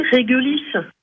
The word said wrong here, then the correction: Réglisse: Non, on dit pas réglisse, on dit pas réglisse. Le nom du groupe, c'est Réglisse. Réglisse, on est d'accord.